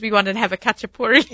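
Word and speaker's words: khachapuri: Oh, see, I can say a kachapuri. – Yes. – Kachapuri. – Uh-huh. – Oh, I said it right. – You did it good.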